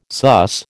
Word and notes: suss: American English

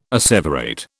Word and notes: asseverate: British English

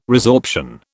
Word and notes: resorption: British English

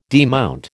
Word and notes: demount: American English

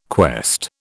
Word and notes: quest: British English

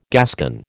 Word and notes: Gascon: American English

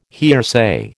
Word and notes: hearsay: American English

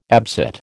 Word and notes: absit: American English

